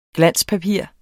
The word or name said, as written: glanspapir